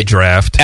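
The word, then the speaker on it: draught: It's both. It actually, oh, really? – That there isn't a rule for it. It could why not drought just, is not wrong.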